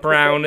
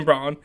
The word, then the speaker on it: Braun: Christian, I know how it says, but I'm going to say the way it's spelled, Braun. It's not Brown. It's Christian Braun.